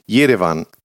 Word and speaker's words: Jerewan: Wir haben gar nicht drüber gesprochen, wie der Titel jetzt ausgesprochen wird. Ich probiere es, ja? – Mach mal. – Jerewan. – Perfekt. – Jerewan? – Ja.